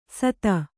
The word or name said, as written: ಸತ